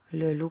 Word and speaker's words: Leloup: Mon nom, c'est Leloup Philippe. Et mon numéro, je sais pas, je connais pas, je suis pas sûr en plus. – Le loup, comme ça se prononce, monsieur? – Oui, tout attaché.